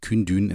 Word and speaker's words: Kundun: Wenn ihr euch dafür interessiert, könnt ihr am Anfang des Films Kündün, also K-U-N-D-U-N geschrieben und Kündün ein, ganz kleines bisschen besser ausgesprochen, minimal.